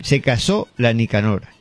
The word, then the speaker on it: Se casó la Nicanor: Espuela de Plata interpretará Seca Sola Nicanor. ¿No? ¿Lo dije bien? Se casó la Nicanor.